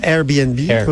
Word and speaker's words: Airbnb: Là, Airbnb, pour bien le prononcer.